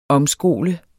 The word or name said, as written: omskole